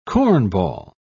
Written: cornball

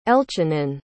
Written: elchanan

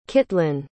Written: Kitlyn

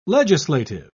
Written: legislative

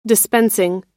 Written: dispensing

